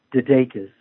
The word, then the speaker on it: DeDakis: That's just J-O-H-N. Last name is DeDakis, and it's spelled D as in dog, E, D as in dog, A, K-I-S as in Sam.